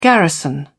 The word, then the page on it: garrison: British English